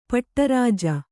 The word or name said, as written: ಪಟ್ಟರಾಜ